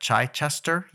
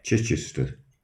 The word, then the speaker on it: Chichester: He's from somewhere called, I'm going to probably mispronounce this, but Chichester, UK. Did I butcher that? – Yes. – How do you say it? – Chichester.